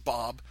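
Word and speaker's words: B'ahb: It's B apostrophe AHB, and it is pronounced Bob because the character was originally started just for me to have fun.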